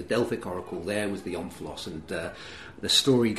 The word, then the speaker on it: omphalos: I pronounce it omphalos.